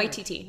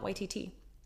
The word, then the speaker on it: Waititi: Waititi, not YTT. – I'm sorry, what? – Waititi. – And not? – Waititi. – Okay. – The emphasis is on the Y. Waititi.